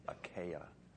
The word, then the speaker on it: Achaia: That's really the right way to pronounce it. Achaia.